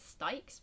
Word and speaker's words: Styx: Yes, River Styx. – Yeah, I was right. Weird intellect. – I think some people pronounce it Stikes, but I say Styx.